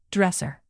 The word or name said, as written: dresser